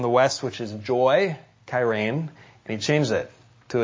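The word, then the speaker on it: kairēn (χαίρειν): And let me tell you how to pronounce the word in Greek, kairēn, because Paul changes it to a sound-alike word, charis.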